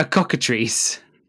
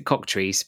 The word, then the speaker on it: cockatrice: A cockatrice. – No. – So some people pronounce it cocktrice, but it's spelt like cockatrice.